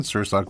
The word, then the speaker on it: Sirsak: By the way, is Tam and Sirsak really how it's pronounced? – Yes, Tam and like salmon, Sirsak like sir and the sock on your foot.